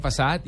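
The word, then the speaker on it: façade: Bueno, pues lo primero es que me dice, mire, se, se pronuncia facade y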